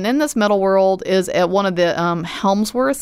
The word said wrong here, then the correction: Hemsworth: Is that how you say it? Helmsworth? Like, Miley Cyrus's? Not her husband, but a Hemsworth. Oh, yeah. – Same difference. It's a metal world with a Hemsworth.